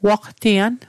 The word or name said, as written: waqtian